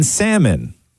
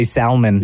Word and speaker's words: salmon: How many people pronounce the L in salmon? – Oh, my dad does that. – I'm going to say a lot. A lot of weirdos say salmon.